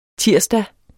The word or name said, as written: tirsdag